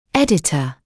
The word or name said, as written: editor